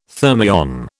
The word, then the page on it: thermion: British English